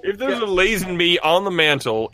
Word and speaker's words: Lazenby: It's pronounced Lazenby.